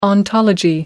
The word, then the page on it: ontology: American English